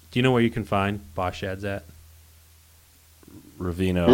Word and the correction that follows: Ravenna: Ravenna. Ravenna. – Ravenna. – it's Ravenna.